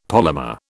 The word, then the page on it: polymer: British English